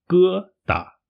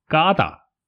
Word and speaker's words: 疙瘩: 疙瘩啊，它的发音是“ 疙瘩”，不过有些中国人会把它发音成“ 嘎达”，呃，这个是不标准、不正确的发音。